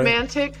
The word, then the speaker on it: Emantic: Emantic Fitzgerald Bradford Jr.